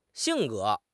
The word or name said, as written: xing4 ge2